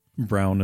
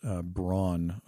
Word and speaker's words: Braun: So for those of you in the rest of the world, you're probably pronouncing it Brown as the Germans do and as they're supposed to. But here in North America, it's been pronounced Braun for many, many years.